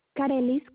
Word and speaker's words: Karelys: Carelis Carrillo. – And can you spell it for me? – K-A-R-E-L-Y-S.